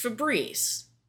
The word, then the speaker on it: Febreze: It's pronounced Febreze. That's how they say it on their commercials.